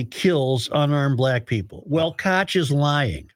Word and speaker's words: Koch: On the other hand, I think there's a real sense that it is something to be celebrated, said Andy Koch, K-O-C-H, Koch. – Koch, they pronounced it Koch on the report.